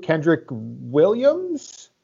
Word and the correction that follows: Kenrich Williams: Kenrich Williams, yes.